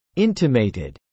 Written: intimated

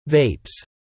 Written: vapes

